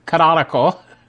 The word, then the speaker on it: Canonical: I have a hard time pronouncing that, Canon ICL, Canonical, is changing dramatically.